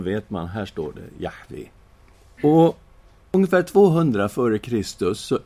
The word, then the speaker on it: Jahve: I det hebreiska Gamla testamentet så finns ju tetragrammet JHVH som då är det heliga gudsnamnet, som vi brukar uttala Jahve.